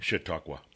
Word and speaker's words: Chautauqua: Chautauqua, New York.